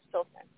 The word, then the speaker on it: Filsan: I'm um, calling from Ohio, and my name is Filsan.